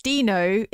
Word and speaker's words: Dino: Dino is pronounced Dino in the name of the Flintstones pet, yes.